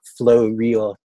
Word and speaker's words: Floreal: I don't even know that thing, but my profile is T-O-N-E. I pronounce that Tony. Mm-hmm. F-L-O-R-E-A-L, Flow Real, Tony Flow Real.